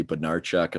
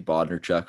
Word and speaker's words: Bodnarchuk: You know, I'm pretty good at the Oilers, although there's a couple guys who I messed up, not in terms of pure pronunciation, but syllable emphasis. Like we have a guy named Riley Bodnarchuk, and I called him Riley Bodnarchuk for the first 2 weeks of the year.